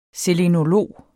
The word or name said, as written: selenolog